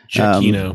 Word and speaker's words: Giacchino: Yeah, and it was Gochino. Oh, uh, what's that? How do you pronounce his name? – Giacchino. Um, Giacchino. – There it's Giacchino.